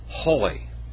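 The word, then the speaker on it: Hoi (οἱ): So how do you pronounce that? Hoi.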